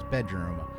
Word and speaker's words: BDRM: Boredom and Romance is really just a continuation of a project I had called BDRM that was being pronounced as Bedroom.